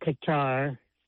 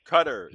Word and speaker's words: Qatar: It's not Qatar. It's pronounced to rhyme with the dairy product butter. – Qatar.